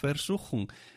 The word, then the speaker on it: Versuchung: Ojo, esta palabra se escribe con V al principio, Versuchung, pero la V en alemán se pronuncia como casi como una F.